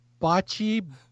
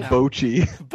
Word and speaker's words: bocce: They might as well have just repeated the Samoa one where they play bocce ball and make it themselves. Is that how I pronounce it? Now I forget. – No, how'd you say it wrong? You said it right. – He's a bochi.